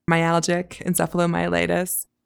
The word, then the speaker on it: myalgic encephalomyelitis: Myalgic encephalomyelitis.